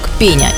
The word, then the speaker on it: пеня: Ударение в этом слове ставится на первый слог – пеня, и во всех падежах он сохраняется.